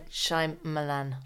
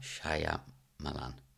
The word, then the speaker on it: Shyamalan: Shyamalan. – Shyamalan.